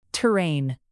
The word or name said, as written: terrain